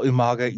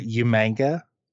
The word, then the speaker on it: Umaga: And he would call Umaga Umanga. And that always got me when he would just pronounce it as Umanga.